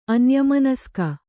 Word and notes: ಅನ್ಯಮನಸ್ಕೆ: anyamanaske

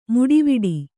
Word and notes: ಮೀಟುವಡೆ: mīṭuvaḍ3

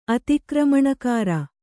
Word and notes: ಅತಿಕ್ರಮಣಕಾರ: atikramaṇakāra

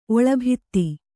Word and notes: ಒಳಬದಿ: oḷabadi

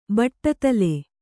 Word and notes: ಬಟ್ಟದಲೆ: baṭṭadale

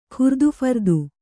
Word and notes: ಖುರ್ದುಫರ್ದು: kannada

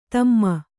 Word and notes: ಧಮ್ಮ: Kannada

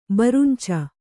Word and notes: ಬರುಂಚ: Kannada

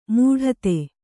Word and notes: ಮಡದಿ: maḍadi

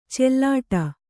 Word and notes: ಚಲ್ಲಾಟ: callāṭa

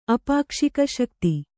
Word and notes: ಅಪಾಕ್ಷಿಕಶಕ್ತಿ: kannada

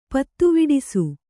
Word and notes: ಪತ್ತುವಿಡಿಸು: pattuviḍisu

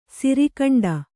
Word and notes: ಸಿರಿಖಂಡ: Kannada; siri khaṇḍe

